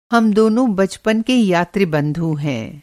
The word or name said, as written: यात्री बंधु